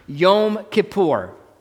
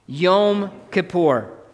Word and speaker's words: Yom Kippur: In Hebrew, it's pronounced Yom Kippur. Say that with me. Yom Kippur.